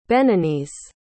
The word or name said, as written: beninese